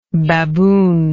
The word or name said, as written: baboonish